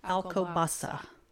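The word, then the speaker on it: Alcobaça: I think it's Alcobasa. – There we go. Alcobasa. It's all C's in there, but one of the C's has a whishy on it, and so it's pronounced with an S.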